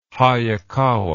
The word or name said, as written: Hayakawa